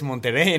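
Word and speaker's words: Monterey: Sí, el Monterrey Car Week, que no está mal pronunciado, es Monterrey en Estados Monterrey.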